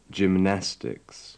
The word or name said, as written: gymnastics